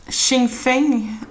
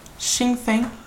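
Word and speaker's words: Xianfeng: Och eh, Xingfeng eh, heter han va? Man uttalar det så? – Det låter nästan som Sinn Fein. – Xingfeng.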